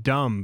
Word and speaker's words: dumb: Yeah, there's no fucking B at the end of dumb, but we don't pronounce the B. It's dumb.